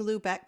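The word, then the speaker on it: Lübeck: Greg wants to know the correct pronunciation of Lubeck. Am I saying Lubeck correctly, Earl? Do you know? – Yes, that's the way I've always heard it.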